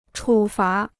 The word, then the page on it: 处罚: chǔ fá